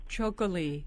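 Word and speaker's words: txakoli: K-O-L-I, right. – Chocoli.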